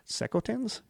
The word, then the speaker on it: Secotan: The hostility began soon after their arrival when the English accused the Secotans, I don't know how to pronounce it, S-E-C-O-T-A-N, Secotans? – That sounds right.